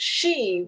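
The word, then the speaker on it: shi (四): Because in Japanese, 4 is pronounced shi, which is the same pronunciation for death.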